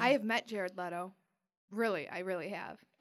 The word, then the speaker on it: Leto: Jared Leto wanted to wear, that's how I say his name, right? You guys? – Yeah.